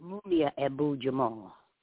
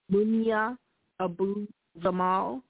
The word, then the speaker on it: Mumia Abu-Jamal: Mumia Abu-Jamal. – Mumia Abu-Jamal.